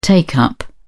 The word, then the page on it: take-up: British English